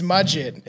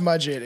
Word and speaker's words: Mudgett: But anyways, born as Herman Webster Muggett. – Muggett. It's Mudgett. It's Mudgett. Pronounced Mudgett.